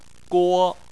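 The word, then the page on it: guo1: tone 1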